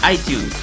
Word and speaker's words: iTunes: No, iTunes no. Que parece, te pareces al otro ya, hablando de Atunes. – ¿Atunes? – Sí. – ¿Cómo se dice entonces? – iTunes.